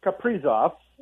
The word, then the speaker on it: Kaprizov: Well, I saw a tweet, by the way, Kaprizov, and I'm going to take forever to pronounce that the right way. But I think you said it right.